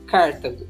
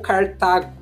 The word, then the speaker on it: Cartago: onde foi fundado Cartago ou Cartago. A pronúncia varia de acordo com a fonte que vocês encontrarem.